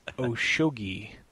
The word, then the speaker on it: Oshogi: It's Oshogi. Mike Oshogi. – Shogi. – Oshogi. Guy. Gi. No, that's right. Gi. Oshogi. He pronounced it phonetically for me.